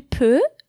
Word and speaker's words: peut: Je peux, P-E-U-X, je peux, tu peux, same thing. Il peut, same pronunciation with a T at the end, P-U-T.